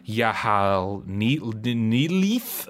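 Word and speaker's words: Y'ha-nthlei: Later on, when you're in the kind of Devil's Reef area, starting to go into Yahal Neelif, however you pronounce it. That was my attempt. – No, that's it. You nailed it.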